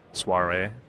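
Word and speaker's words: soirée: Soir- soiree, that's how you say it.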